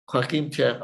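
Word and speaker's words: Joachim Trier: pero ¿cómo debo? Cómo debo? Joaquín Trier. Joaquín Trier, ok, perfecto.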